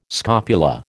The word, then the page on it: scopula: American English